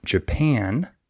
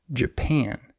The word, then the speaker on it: Japan: In this word, the second syllable is stressed Japan. However, many English learners stress the wrong syllable. They say Japan. And that's wrong. But it's very easy to notice if a teacher points that out to you, and it's very easy to fix. You just stress the other syllable.